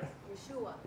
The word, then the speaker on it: Yeshua: Am I saying that right? – Yeshua. – Yeshua. Thank you.